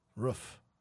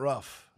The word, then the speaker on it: roof: Yeah, it's roof. – Roof. – It's not rough.